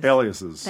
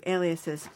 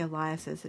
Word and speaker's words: aliases: I always mispronounce this. – Aliases. – Thank you. Aliases. Okay. I always say aliases. It's very embarrassing.